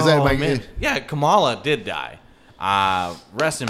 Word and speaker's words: Kamala: Like, because you know, Kam- let me let me correct you, Kamala. – Kamala, Ka- Kamala. – Right. The emphasis is on the Ka.